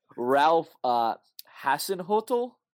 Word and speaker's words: Ralph Hasenhüttl: Ralph Hasenhüttl.